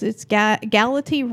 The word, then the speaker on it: Galati: It's Galati rhymes with galaxy.